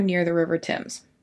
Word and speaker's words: Thames: Also, just in case it happens, I always want to read it as the Thames. – Okay. – And I know some people call it that, but apparently in England it's the Thames. – Uh-huh. – So I'm trying to say it correctly.